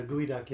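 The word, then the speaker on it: geoduck: for those listening who don't know what a gooey duck is, it's spelled G-E-O, duck, and obviously you're familiar with them.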